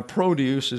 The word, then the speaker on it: produce: Now, produce is interesting because you can also have the word spelled the same way, pronounced produce.